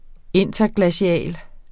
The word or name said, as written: interglacial